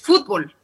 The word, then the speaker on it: fútbol: A ver, pero nosotros en México decimos fútbol, para nosotros la vocal que pronunciamos con más fuerza es la O y por lo tanto tenemos una palabra aguda y recuerden que las palabras agudas son las que tienen la carga tónica en la última sílaba.